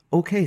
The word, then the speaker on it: OKeh: Heinemann, a German-American entrepreneur, OK started as the phonograph division of the Otto Heinemann Phonograph Company. The name OK itself is a play on Heinemann's initials, O-K-E-H, which is how it's spelled, and would soon become synonymous with quality recordings across a variety of genres.